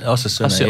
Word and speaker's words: Osasuna: Osasuna,